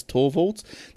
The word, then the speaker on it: Torvalds: Now, anyway, get back to Linus Torvald. Oh, is that how you pronounce his last name? Linus Torvalds.